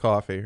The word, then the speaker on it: kufi: It's pronounced coffee, right? – Coffee.